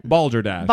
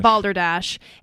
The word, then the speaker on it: Balderdash: It's kind of like Boulder Dash. Is that how you say it? – Balderdash. – Balderdash.